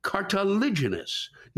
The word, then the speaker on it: cartilaginous: The third syllable in cartilaginous is stressed, unlike its root cartilage, where the first syllable is stressed, and which I'm sure you know how to say cartilage, right? Don't let that fact throw you off, Lewis, when you say cartilaginous still with that soft G. And the last syllable is pronounced nus, as in nuts, Lewis. Repeat, cartilaginous.